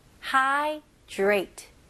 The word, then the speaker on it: hydrate: 여기서는 D 발음보다는 D가 R랑 같이 합칠 때 J 발음이 많이 나와요. 그렇게 읽으셔야지 훨씬 더 쉬워요. 그래서 Drate. 원어민들도 힘들거든요. 그래서 J 발음으로 Drate. Drate. Hydrate.